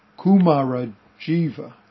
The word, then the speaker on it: Kumarajiva: So, Dharma Master Chow was a student of Kumarajiva, that's the pronunciation.